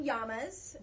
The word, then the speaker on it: llama: And Michael loves llamas, or as he calls them, llamas. And so I had to make sure I got baby ones in there. – That's the correct pronunciation.